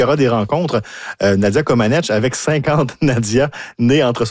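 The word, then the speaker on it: Comăneci: On parle d'un spectacle de variété auquel assistera bien sûr Nadia Comaneci. Hein. On a appris cette semaine qu'on ne doit plus prononcer le I.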